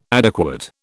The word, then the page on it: adequate: British English